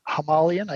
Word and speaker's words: Himalayan: Have you guys heard anything about like people's, you know, enjoyment of the Himalayan? Like, is it reliable? Is it, I don't know. – We have one acquaintance in North Carolina that has a Himalayan.